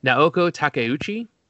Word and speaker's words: Naoko Takeuchi: Sailor Moon started, is created by Naoko Takeuchi.